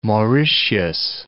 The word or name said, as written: Mauritius